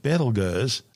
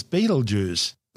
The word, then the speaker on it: Betelgeuse: Although we're calling it Betelgeuse, it's also commonly known as Betelgeuse. Both are mispronunciations of the original Arabic name Ibtilyaza, meaning the hand of the big man being Orion the Hunter.